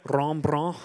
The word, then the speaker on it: Rembrandt: This is called The Return of the Prodigal Son by a guy named Ron Brant. I can't say his name right. It's Russian. Rembrandt. Rembrandt. Rembrandt.